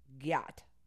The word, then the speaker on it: gyatt: The next one is giat. GYATT. It's pronounced giat.